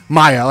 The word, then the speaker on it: Mia: Maya. I think it's Maya Goth. M-I-A.